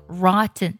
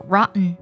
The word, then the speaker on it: rotten: 腐烂的英文呢是 rotten，拼作 rotten，你也可以读作 rotten。在美式的发音当中，经常呢将字母 t 呢进行鼻音爆破，读作 rotten， rotten。